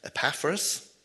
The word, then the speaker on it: Epaphras: So Paul, it was probably, as far as we know, during Paul's stay in Ephesus, that his co-worker, Epaphras, as you pronounce that absolutely right, Kathy, first brought the good news to Colossae and established a church there.